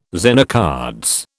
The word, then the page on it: Zener cards: British English